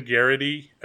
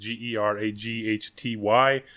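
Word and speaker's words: Geraghty: But Ben Garrity, pretty sure that's how you pronounce it. Garaghty.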